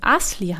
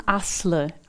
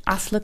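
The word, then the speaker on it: Aslı: Und mein Vorname würde auch nicht Asli heißen, sondern Asle. Also ich habe türkische Wurzeln. – Okay. – Und im türkischen Alphabet, da gibt es das I ohne Punkt. Mhm. Und also wohl Asli, also Asle, wird ohne I-Punkt geschrieben und Kilic, auch ohne I.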